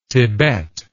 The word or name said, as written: Thibet